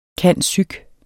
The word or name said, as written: cand.psych.